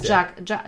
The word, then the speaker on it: Jacques: Jacques also Jacques, J-A-C-Q-U-E-S. – E ja, Jacques. – Ja, aber warum, warum schreibt man dann nicht einfach nur J-A-C-Q? – Weil Französisch.